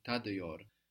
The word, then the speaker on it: tadıyor: The T is softened to a D by the vowel, and that vowel is a dotless I. Tadıyor. – I taste, I'm tasting. – Tadıyorum.